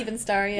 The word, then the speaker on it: Evenstar: Yeah, Evenstar, yeah.